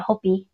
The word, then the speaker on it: Hopi: Honestly, I don't know about the burial, because women in Hopi, that's how you say it, Hopi.